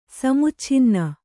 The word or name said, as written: ಸಮುಚ್ಛಿನ್ನ